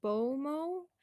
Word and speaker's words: bomoh: A bow. Uh, looks like you say it as bomoh.